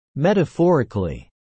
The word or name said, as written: metaphorically